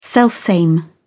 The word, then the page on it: selfsame: British English